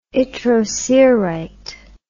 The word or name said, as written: yttrocerite